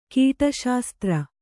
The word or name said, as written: ಕೀಟಶಾಸ್ತ್ರ